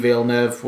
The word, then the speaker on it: Villeneuve: First Sicario, directed by Denis Villeneuve, was superb.